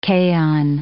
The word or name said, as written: kaon